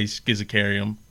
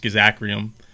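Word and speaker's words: Schizachyrium: We've talked about what I say schizacarium, but also is pronounced schizacrium, which is right.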